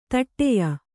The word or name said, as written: ಧಡಯ